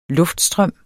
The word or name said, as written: luftstrøm